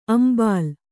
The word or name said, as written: ಅಂಬಾಲ್